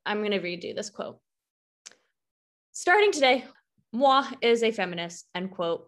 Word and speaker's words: moi: Alyssa does write that Miss Piggy even has said, quote, starting today, Moy is a feminist while accepting an award. – I think she says moi. – Moi. Is that how you say it? M-O-I. – Because it's French.